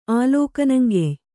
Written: ಆಲೋಕನಂಗೆಯ್

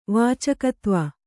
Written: ವಾಚಕತ್ವ

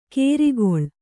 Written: ಕೇರಿಗೊಳ್

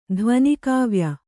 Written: ಧ್ವನಿಕಾವ್ಯ

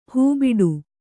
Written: ಹೆಬ್ಬಟೆ

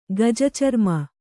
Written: ಗಜಚರ್ಮ

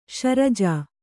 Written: ಶಿರಾಜಿ

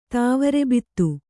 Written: ತಾವರೆಬಿತ್ತು

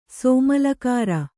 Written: ಸೋಮಲಕಾರ

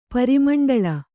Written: ಪರಿಮಂಡಲ

